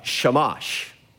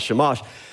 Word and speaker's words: Shamash: You go to Mesopotamia, you have the sun god Shamash. That rhymes with Hebrew. So in Hebrew, the word for sun is Shemesh, Shemesh, Shamash. So the sun god is Shamash.